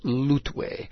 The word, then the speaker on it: L'Outaouais: Let me spell that for you. It's L'apostrophe O-U-T-A-O-U-A-I-S, and it's called L'Outaouais, a First Nations name, and Peter will tell you more about that.